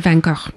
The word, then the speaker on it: Van Gogh: and I went to the Van Gogh Museum.